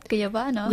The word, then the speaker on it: guayabano: And then I tried guayabano, which apparently it's like something typical too. – You said it right, guayabano.